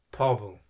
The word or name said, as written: pobel